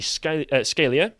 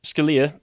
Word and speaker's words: Scalia: Chief Justice William Rehnquist dissented from the majority and was joined by Justice Antony Scalia. – Scalia. – Sorry, I'm just not pronouncing people's names right today.